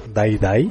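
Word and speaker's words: Dai Dai: ¿Cómo se pronuncia? ¿Die Die o Day Day? – Die Die. – Die Die.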